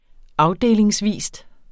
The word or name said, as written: afdelingsvis